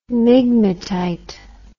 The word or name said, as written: migmatite